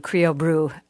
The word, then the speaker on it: CrioBru: So Creo Brew is spelled C-R-I-O-B-R-U, Creo Brew.